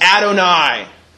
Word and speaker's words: Adonai: And if you pronounce that word from Hebrew, it's pronounced Adonai. Adonai.